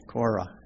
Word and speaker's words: Korah: Psalm 84 is written by the sons of Korin. Am I pronouncing that right, honey? Korin? You can look at it in your Bible. Korah. Yeah, Korah. Okay, there we go.